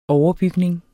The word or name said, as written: overbygning